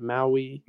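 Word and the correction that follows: Mawae: Mawai. – Mawai. – Yeah, Kevin Mawai.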